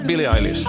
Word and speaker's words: Billie Eilish: Hur uttalar man Billie Eilish då? Billie Eilish. – Ja, Billie Eilish.